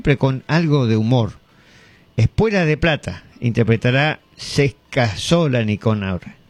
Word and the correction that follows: Se casó la Nicanor: ¿No? ¿Lo dije bien? Se casó la Nicanor.